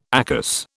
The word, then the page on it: ACAS: British English